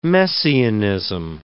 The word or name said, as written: messianism